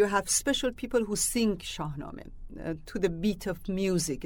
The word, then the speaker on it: Shahnameh: That's the Shahnameh.